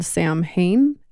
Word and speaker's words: Samhain: So I might say the name wrong, but there was a holiday called Samhain. S-A-M-H-A-I-N.